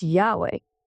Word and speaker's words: Yahweh: The name for God in the Old Testament is Yahweh, but it's spelled without vowels, so it's Y-H-W-H. Now, these letters make up the exact sound of a breath, where Y is the inhale and way is the exhale.